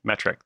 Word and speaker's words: metric: But the list also includes metric, which for years I mispronounced as matrix.